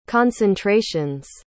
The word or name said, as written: concentrations